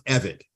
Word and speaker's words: Evette: And her last name, by the way, everybody, just so you can clear it out, it's pronounced Evett.